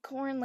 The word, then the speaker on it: Korn: My name's Sarah Korn. Yeah, Korn like the band. I guess Korn's like a unique name. But as a kid, people would be like, Korn, it rhymes with porn.